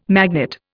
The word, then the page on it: magnet: Indian